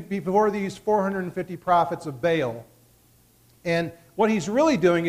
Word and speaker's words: Baal: Baal. For those of you who are technical, the pronunciation is probably Baal, but most people say Baal.